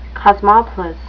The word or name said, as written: cosmopolis